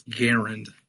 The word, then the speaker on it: Garand: I mean, his name was Garand. That's how we pronounce his name.